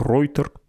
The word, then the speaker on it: Reuters: И вы поймете, кстати, что он из Германии, поскольку «Рейтер» он произносит как «Ройтер», то есть совершенно по-немецки трактует сочетание латинских букв «э» и «у».